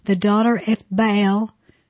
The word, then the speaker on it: Baal: Now, it's pronounced Baal, mm-hmm. Baal. – It's supposed to have a glottal stop.